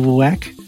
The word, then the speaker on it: WWAC: I'm the editor-in-chief of WWAC. There is some debate as to how that's pronounced, but I pronounce it WWAC.